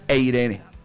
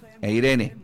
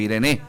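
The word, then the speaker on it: eirene (εἰρήνη): En la apertura de ayer, ya que está Daniel ayudándome, la palabra paz en el original, en el griego, es eirene, eirene, así se pronuncia, eirene o irene.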